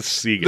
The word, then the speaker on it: Siegen: So it's not Sergei and Merkins? – Merkins. I'm going to go with Merkins, but it is Siegen.